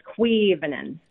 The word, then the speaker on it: Quivanen: Almost. The emphasis is on the first syllable. It's Quivanen.